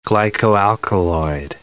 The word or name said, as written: glycoalkaloid